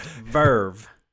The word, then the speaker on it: VRV: And if you want to, Verve is spelled V-R-V, but pronounced Verve.